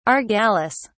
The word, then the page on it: argalis: British English